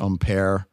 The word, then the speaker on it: upir: Now, you know, I had to look up, you know, it's spelled U-P-I-R. They pronounce it almost like there's an M in there, umpire.